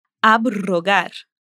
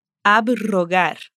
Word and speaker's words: abrogar: E quando o R está depois dos prefixos ab, sub e post. Nesses casos, vamos pronunciar os prefixos separados do R que vem em seguida. Por exemplo, abrogar, abrogar, subrogar, subrogar, postromântico, postromântico excelente, talvez você esteja pensando: como que eu vou lembrar de todos esses casos?